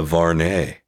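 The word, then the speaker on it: Vuarnet: But while in New York, I ran across a Varnay store. – Hey, throwback. – And so not many people know the brand Varnay. But in the 80s, it was super rad. – The tea is silent.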